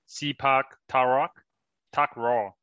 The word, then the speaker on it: Sepak Takraw: Oh, this, have you heard of anything called Sepak Takraw? So it's spelled as Sepak and then Takraw. Sepak Takraw.